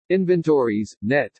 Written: INVENTORIES NET

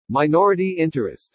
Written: MINORITY INTEREST

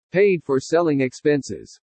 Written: PAID FOR SELLING EXPENSES

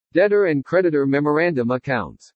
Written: DEBTOR AND CREDITOR MEMORANDUM ACCOUNTS

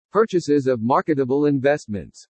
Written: PURCHASES OF MARKETABLE INVESTMENTS

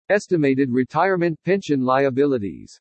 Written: ESTIMATED RETIREMENT PENSION LIABILITIES

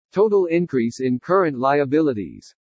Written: TOTAL INCREASE IN CURRENT LIABILITIES

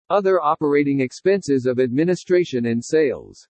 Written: OTHER OPERATING EXPENSES OF ADMINISTRATION AND SALES

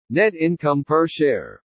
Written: NET INCOME PER SHARE

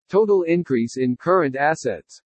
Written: TOTAL INCREASE IN CURRENT ASSETS

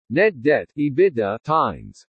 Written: NET DEBT-EBITDA TIMES

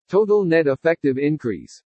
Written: TOTAL NET EFFECTIVE INCREASE

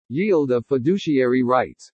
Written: YIELD OF FIDUCIARY RIGHTS

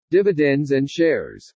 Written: DIVIDENDS AND SHARES

